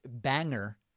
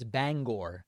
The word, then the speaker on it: Bangor: Next, my pronunciation of Bangor, Maine, Sabrina from Ellsworth, Maine, wrote in and said, there's no er, it's or. I pronounced it banger, and Sabrina says it's Bangor.